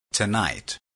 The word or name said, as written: tonight